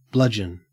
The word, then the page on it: bludgeon: Australian English